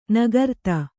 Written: ನೇಕಾರಿತಿ

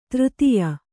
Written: ತೃತಿಯ

